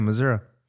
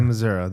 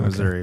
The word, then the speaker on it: Missouri: That's how you say it, Missouri. That's how they say it. – I can never remember. – They say Missouri. They don't say Missouri.